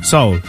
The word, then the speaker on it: Seoul: I start a new job in Seoul next week, or Seoul, is it? – Seoul. – I thought it was a good career move. – Korea. Okay. – Yeah. – I get it, yeah. – Seoul.